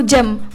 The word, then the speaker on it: gem: Kayak sebenarnya dia tuh tulisannya G-E-M, tapi kenapa dipanggil, dibacanya itu gem, bukan gem atau gam, tapi gem gitu kan. Itu karena emang kebanyakan dalam bahasa Inggris tuh huruf G dibacanya J.